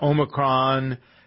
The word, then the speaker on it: Omicron: You've nailed that pronunciation. – It took you a week. For those of you who didn't hear, I was calling Omicron. – Omicron, like a tech company, yeah.